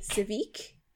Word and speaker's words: Tsvyk: Sivik. – T-S-V-Y-K. And I heard it pronounced earlier and it sounded like Civic, so I was like a car? – Honda Civic.